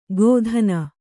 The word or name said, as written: ಗೋದಾನ